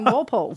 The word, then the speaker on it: Walpole: Did I pronounce it correctly? – Yes, Walpole.